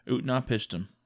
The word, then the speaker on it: Utnapishtim: Utnapishtim. You listened to this on audio. So is that how they pronounce it? – Yep. – Utnapishtim, whom they call the Faraway, or a Faraway.